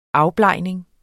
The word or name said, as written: afblegning